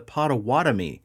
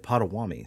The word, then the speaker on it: Potawatomi: Speaking of being shitty, this is pronounced the Potawatomi. I think I said Potawatomi. So corrections all around.